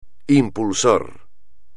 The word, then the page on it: impulsor: espagnol